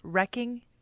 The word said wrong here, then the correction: wreak: I pronounce it wrecking havoc. – Right. It's W-R-E-A-K. – And it's, no, what is it? It's reek.